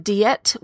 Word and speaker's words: Diet: I'm just kidding. It's actually pronounced Diet, which means assembly of Worms, the German pronunciation in for the town in which this meeting took place.